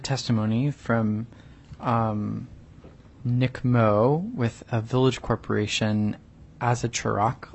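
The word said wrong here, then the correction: Azachorok: It's pronounced Azazarak.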